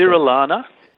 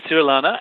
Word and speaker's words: Cirolana: And so how do you say the proper name? I was getting the pronunciation wrong, so I should leave it to the expert. – So it's Cirilana. Yep, Cirilana, and they were found by Mr. Harford, probably in the 1800s, and so they got the name was Latinized to harfordi.